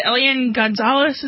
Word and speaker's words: Elian Gonzalez: And I don't know, because I remember when they had the Elian Gonzalez, is that how you say his name? – That's correct.